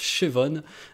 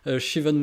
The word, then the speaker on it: Siobhan: et je crois que ça se prononce Siobhan. Siobhan Wilson, en fait, c'est une de mes chanteuses préférées.